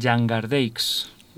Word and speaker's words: Llangardaix: que se titula El Fond y el Llangardaix. – Llangardaix se pronuncia, pero muy bien lo has dicho, sí.